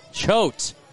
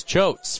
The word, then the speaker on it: Choate: Here is Choate's first pitch, and it's in the dirt, and the count at 1-0. And again, another tall lefty for San Jose. Jack Choate, C-H-O-A-T-E. The pronunciation is C-H space O-A-T.